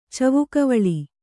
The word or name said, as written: ಚವುಕವಳಿ